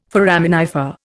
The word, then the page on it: foraminifer: Indian